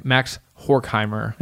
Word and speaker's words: Max Horkheimer: So, there was this dude named Max Horkheimer. Am I pronouncing that right? How's my German? – Say it without laughing. – Okay. Max Horkheimer. – There we go.